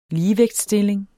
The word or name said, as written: ligevægtsstilling